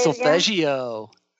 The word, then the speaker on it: solfeggio: How do you say solfeggio. – There you go.